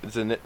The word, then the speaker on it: Zenitsu: And like what you're talking about, like, how is Tanjiro, Inosuke, and Zenitsu? Well, I mean, that dude, what's his name, bro? I'm going to pronounce it wrong now. I've been saying Zenitsu. – Yeah, Zenitsu, bro.